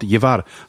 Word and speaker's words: llevar: Also insbesondere im Spanischen gibt es so eine Buchstabenkombination LJ mhm. zum Mitnehmen, äh, para llevar heißt das. Und so, wenn du das liest und, und, und aussprechen willst beim Lesen, wirst du immer über dieses LJ stolpern.